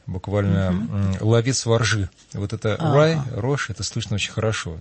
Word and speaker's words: rye: В англосаксонских языках это «рай», пишется «R-Y-E», если по-английски сейчас даю азбуку, алфавит.